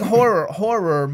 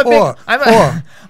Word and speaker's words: horror: I'm a big horror movie fan. I always say, how do you say horror? – Horror. – Okay. – Yeah, Jimmy says whore.